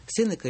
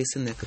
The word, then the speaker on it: Сенека: Это тоже театр имени Сенека или Сенека, как правильно? – По-разному. Они произносят по-разному. Александр Сергеевич по одному, энциклопедические словари иногда по-другому.